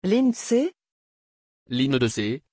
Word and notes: Lyndsay: French

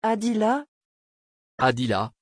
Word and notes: adila: French

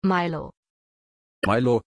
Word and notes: Mailo: German

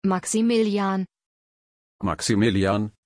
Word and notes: Maximillian: German

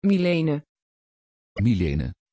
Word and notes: Mylene: Dutch

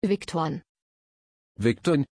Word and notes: viktorin: German

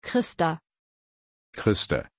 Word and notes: christer: German

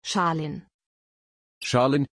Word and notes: Shalin: German

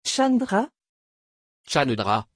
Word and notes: chandra: French

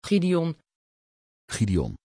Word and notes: gideon: Dutch

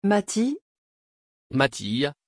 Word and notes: Mattiz: French

